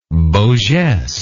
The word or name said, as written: beau geste